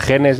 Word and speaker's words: genes: El motivo, que claro, ella habla de jeans, claro, jeans, o sea, genes y jeans vaqueros se pronuncia en inglés exactamente igual.